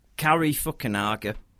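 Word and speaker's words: Cary Fukunaga: You've got Kari Fukunaga. That is how you pronounce his name, isn't it? – It is.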